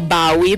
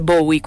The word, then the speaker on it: Bowie: E qui in redazione ci stavamo chiedendo: ma si legge David Bowie o Bowie? – Ecco, io dico Bowie perché il suono B-O-W lo leggo Bao, quindi unito alla I e finali leggo Bowie, però non lo so, tanta gente dice Bowie, quindi fateci sapere.